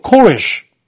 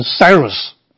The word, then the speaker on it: Cyrus: 塞鲁士呢，其实大概比较受到那个英文的影响，因为这个波斯的我们称作古列大帝或者居鲁士大帝的话，他在原来文字里面念作 Koresh，所以我们中文把它翻成那个古列啊，应该是正确。但是他在另外的一些文字里面啊，就把它翻译作居鲁士，这其实跟原来文字啊应该是比较接近。但是翻英文的话呢就不一样，英文的话你要念成 Cyrus，所以呢难怪有些中文的翻译把它翻译作塞鲁士啊，是从这边来的。